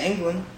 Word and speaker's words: Englund: So Robert, how would you pronounce his last name, Lonnie? Would you say England? What is that? – Oh yeah, England.